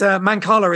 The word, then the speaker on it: Mancala: Yes, it's Mancala is the right way to pronounce it.